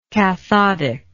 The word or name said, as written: cathodally